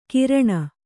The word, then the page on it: ಕೀರ್ಣ: Kannada